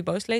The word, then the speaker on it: Beausoleil: En Bobby Bosoli, of Bobby Bosley, Bobby Bosley, zo zeg ik het goed.